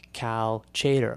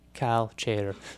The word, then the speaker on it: Chater: kind of like Cal Chater, which you might think is Chatter, but no, Cal Chater.